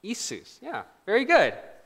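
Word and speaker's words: Isus (Иисус): But it's Isus. This is the name of Jesus in Russian, Isus.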